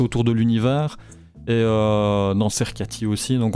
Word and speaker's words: Sercati: Il y a la page bêtement de Cercati, hein, ça s'écrit comme ça s'entend. – S-E-R-C-A-T-I. – La page Facebook, c'est Cercati ?